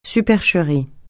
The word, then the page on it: supercherie: francais